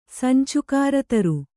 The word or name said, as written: ಸಂಚುಕಾರತರು